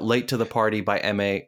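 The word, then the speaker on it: Emei: By the way, M.A. is spelled E-M-E-I. E-M-E-I.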